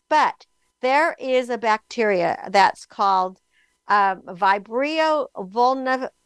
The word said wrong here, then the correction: Vibrio vulnificus: Uh, let me get this right. Vulnificus, uh, Vibrio vulnificus, and it's spelled V I B R I O V U L N I F I C U S.